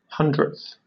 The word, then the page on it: hundredth: British English